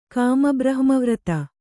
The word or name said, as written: ಕಾಮಬ್ರಹ್ಮವ್ರತ